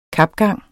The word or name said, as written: kapgang